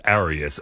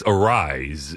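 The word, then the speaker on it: Arráez: By the way, on that Luis Arise idea, I think the caller thought it was Arias. Arise is the proper pronunciation.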